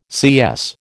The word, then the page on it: CS: American English